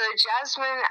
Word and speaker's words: Jasmin: So Jasmin, as in Jasmin, my last name is spelled Shojai.